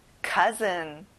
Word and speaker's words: cousin: 사촌, cousin. 이거는 아 소리를 내보세요. Cousin.